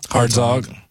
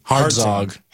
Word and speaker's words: Hartzog: Hartzog. – What? – Hartzog. – Hartzog. Spell it. – H-A-R-T-Z-O-G.